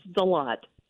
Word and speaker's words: Deslatte: It's Deslatte.